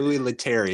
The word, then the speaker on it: Louis Leterrier: And I forgot about this until the credits showed up and I was like, Louis Leterrier. I think that's, I'm not sure if I'm pronouncing his name right. – Louis Leterrier. Yeah.